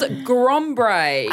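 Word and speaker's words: Grombre: And it's called Grombray. – I was wondering how you pronounce it. – That's what I assume. It's G-R-O-M-B-R-E, because I think it's like ombre, which is like balayage. But grey.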